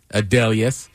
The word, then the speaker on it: Adelius: They're like, actually, it's pronounced this way. Adelius.